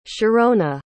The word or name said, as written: sharona